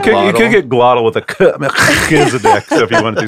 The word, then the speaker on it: Melchizedek: How do you pronounce this guy's name? I've always pronounced it Melchizedek.